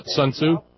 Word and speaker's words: Sun Tzu: Sun Tzu.